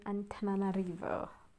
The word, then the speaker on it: Antananarivo: This is a prison in Antananarivo, Madagascar. I heard him say it like that, so yeah.